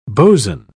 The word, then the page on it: bo's'n: British English